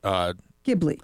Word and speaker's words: Ghibli: Let's see if our producer knows how to pronounce that. G-H-I-B-L-I. – Ghibli. I've heard it pronounced. – Ghibli. – I don't want to say it's all on me. You know, I prefer to pronounce Studio Ghibli. Yeah. – Then I got it right the first time.